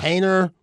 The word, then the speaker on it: Haener: Harner, H-A-E-N-E-R. Yeah. – Hainer.